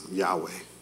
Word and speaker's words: Yahweh: It's a Hebrew word that just says YHWH, which we pronounce Yahweh.